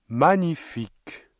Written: magnifique

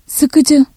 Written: sıkıcı

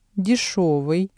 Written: дешёвый

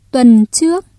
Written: tuần trước

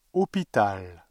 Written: hôpital

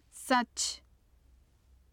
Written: sac

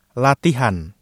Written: latihan

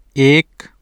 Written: ایک

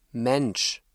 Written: Mensch

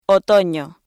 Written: otoño

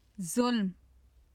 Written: zulm

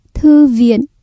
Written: thư viện

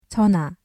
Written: 전화

